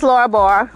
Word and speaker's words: Laura Barr: Hey guys, this is Laura Barr with Keeping It Real with Laura Barr Extended. My name is spelled L-A-U-R-A-B-A-R-R.